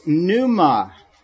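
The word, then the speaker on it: pneuma (πνεῦμα): Now, the other Greek word I want to draw your attention to as well is pneuma. Pneuma. P-N-E-U-M-A.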